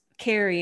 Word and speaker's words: Cari: My name is Carrie and it's spelled C-A-R-I.